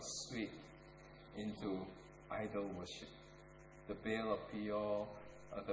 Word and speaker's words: Baal: B-A-A-L, Baal. Or as the Hebrew pronounce it, Baal.